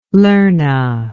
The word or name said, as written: lerna